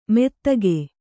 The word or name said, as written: ಮೆತ್ತಗೆ